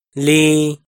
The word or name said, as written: lis